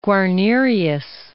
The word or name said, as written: Guarnerius